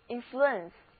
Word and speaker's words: influence: 我们在词根 flu 的前面加上前缀 in，这个 in 是表示在什么什么的里面，然后呢我们再在词根的后面加上名词后缀 ence，组成了这个单词就是 influence， influence，字母组合 ue 读作 ur， influence。